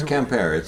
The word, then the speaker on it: Quimper: No, it's Quimper. It's a funny, it's hard pronunciation, okay. But yeah, it's Quimper.